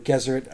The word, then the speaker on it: Gesserit: and then the Bene Gesserit or Gesserit, however they say it, because I feel like it's a hard G.